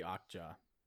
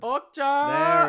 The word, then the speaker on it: Okja: I also like the movie Okja. – That I don't think that's how you pronounce that word. – Okja! – There it is.